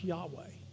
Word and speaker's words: Yahweh: So later on, they added the initials, three vowels, A and E, and they put the A, and it was pronounced Yahweh.